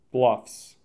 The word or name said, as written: Bluffs